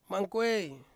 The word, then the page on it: mangkuei: Ansus